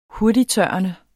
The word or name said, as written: hurtigttørrende